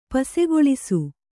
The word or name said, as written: ಪಸಗೊಳಿಸು